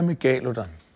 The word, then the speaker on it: megalodon: Så den korrekte er megalodon? – Hvis det skal være den latinske udtale, ja.